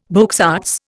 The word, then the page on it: beaux arts: Indian